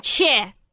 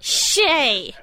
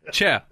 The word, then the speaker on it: Che: Che. – Not Shay. – But it's Shay Chic, and that's the T-shirt, right, Rob? – Absolutely. – Che rhymes with heh.